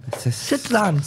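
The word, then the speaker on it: Citroën: There's probably what's the French pronunciation? – Citroen. – Citroen.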